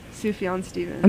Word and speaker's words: Sufjan Stevens: Sufjan Stevens.